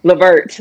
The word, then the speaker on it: Levert: Levert, Levert.